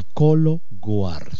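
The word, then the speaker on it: Cologuard: Por favor, ¿qué cosa es el colo guard? Así se escribe, G-U-A-R-D. Guard, que es guardar en inglés, o cuidar. Colo guard se pronuncia.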